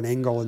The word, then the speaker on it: L'Engle: Am I saying that right? – Yes. – Is that a real name? – It is. – Seems like her name was Madeleine L'Engle and she was like, but the French.